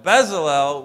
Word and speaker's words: Bezalel: And here in Exodus, we read Bezalel, and I'm going to pronounce it that way. If you want to pronounce it differently, tomato, tomato. All right, but Bezalel was filled with the Spirit.